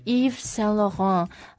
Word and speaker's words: Yves Saint Laurent: 所以 Yves Saint Laurent, 我们就它不是 Yves, 就是 Yves, right?